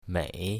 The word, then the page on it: mei3: mandarin